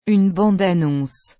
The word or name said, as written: une bande-annonce